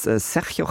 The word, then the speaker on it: Sergio: Also das G, wenn dann I oder so kommt, da wird immer wie CH gesprochen und Okay. Also Sergio Jaén, der macht aktuell wohl die gefragtesten ESC-Stagings, unter anderem auch das von JJ.